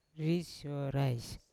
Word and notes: ri syorais: Yawa

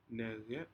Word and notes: necec: Aneityum

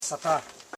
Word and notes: satan: sa.tan; Koro

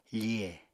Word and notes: fihe: apatani

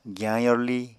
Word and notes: giyañ yorli: Apatani

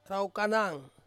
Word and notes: raukanang: Ansus

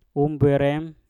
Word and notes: umbwerem: mpur

